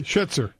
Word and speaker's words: Scherzer: Se dice así, Scherzer, ¿no? – Sí, Scherzer.